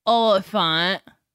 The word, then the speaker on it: Olyphant: Timothy Olyphant. – Yeah. – Did I say his name right? – Yeah.